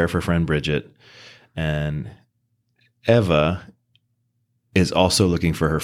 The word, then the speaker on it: Eva: It's E-V-A, but it says in the book that it's pronounced like Eva or something. It's not Eva.